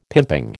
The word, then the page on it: pimping: American English